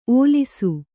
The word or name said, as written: ಓಲಿಸು